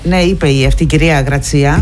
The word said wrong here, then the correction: Γρασία: Η κυρία όχι, συγγνώμη, η Γρασία.